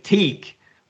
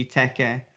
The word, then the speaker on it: Teke: Yeah, well, I can see if you're pronouncing it in English, you're probably going to think Teak. But, you know, phonetically and with the hiragana in Japanese, it would be Tekka. So, yeah. Anyway, T-E-K-E.